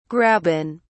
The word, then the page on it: graben: British English